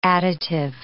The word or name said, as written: additive